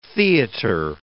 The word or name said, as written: theatre